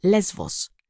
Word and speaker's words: Lesbos: In Greece, Lesbos is pronounced Lesvos and is often referred to as Mytilene after its capital.